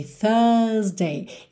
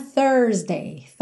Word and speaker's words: Thursday: Thursday, olha aqui, Thursday. Ele alongou esse som do U e não fez o R enrolado, tá? Numa pronúncia mais americana, Thursday, com a porta da perna esquerda ali no meio, tá?